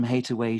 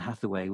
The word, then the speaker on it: Hathaway: I hate from hate away she threw and save my life, saying not you. is the couplet. And Hateaway and Hathaway were alternate pronunciations of of his wife's maiden name.